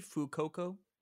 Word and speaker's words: Fuecoco: There's Fuecoco. – I think Fuecoco. – Yeah, like fuego, maybe Fuecoco. – That'd be a good pronunciation.